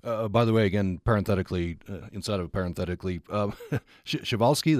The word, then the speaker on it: Przewalski: But it's pronounced Przewalski, even though the spelling is a little bit interesting.